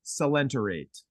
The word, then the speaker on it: coelenterate: I think it's coelenterate. The C-O-E is like a say sound.